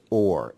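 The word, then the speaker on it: or (אור): The first letter of each word is silent. There are two silent letters in Hebrew, ayin and aleph. So this other word is also pronounced or, exactly the same way.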